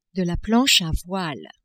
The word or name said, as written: planche a voile